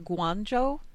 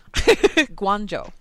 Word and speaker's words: Guangzhou: 6606 in the chat channel says pronounce it Guangzhou, apparently, because they built a hospital there. So that's good. Guangzhou.